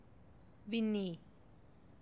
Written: binni

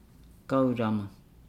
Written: gaulama

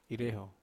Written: reho

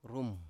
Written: rum

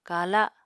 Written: kala

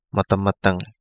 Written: MadaMadangi

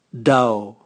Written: dao

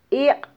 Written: iq

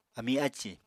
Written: ami ácì

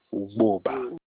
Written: Ugbuba

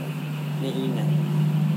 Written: nehina

